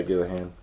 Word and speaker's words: Gillihan: Yeah, Gillihan.